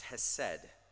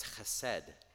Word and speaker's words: chesed: Here is hesed. It's one of the richest words. Danae wanted to make sure that I said that right. It's chesed. She was clear about that.